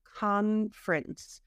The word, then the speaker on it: conference: Annual conference. We eat that middle syllable.